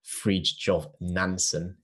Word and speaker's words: Fridtjof Nansen: So there was a Norwegian bloke called Dr. Fridtjof Nansen, who...